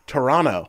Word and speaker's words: Toronto: And you're not supposed to pronounce the second T, is what I heard. Toronto.